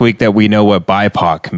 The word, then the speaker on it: BIPOC: I was going to say, CBS is requiring a certain percentage of people in every writer's room to be biopic. – What? – Black, indigenous, people of color. – Oh, okay. – Oh, is that a biopic? – BIPOC. – It's pronounced BIPOC. Biopic. Yeah. It's like a biography.